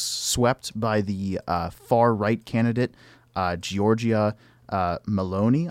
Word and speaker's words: Giorgia Meloni: So, yes, Giorgia Meloni, as they say.